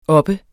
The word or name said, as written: oppe